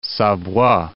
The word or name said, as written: Savoie